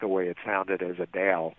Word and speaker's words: Adele: She did she was doing a Q&A with fans, and one of the fans, you know, addressed her as Adele with the emphasis on the second syllable. – Yeah. – And she and Adele said, "I love that." She said my name perfectly.